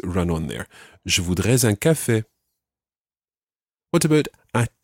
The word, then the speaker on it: je voudrais: The correct way to say this is je voudrais. Je voudrais. Um, I would like a coffee.